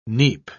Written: neap

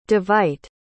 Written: davite

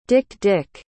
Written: dik-dik

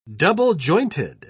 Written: double-jointed

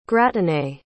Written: gratinée

